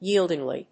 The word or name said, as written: yieldingly